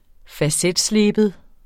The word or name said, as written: facetsleben